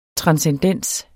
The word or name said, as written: transcendens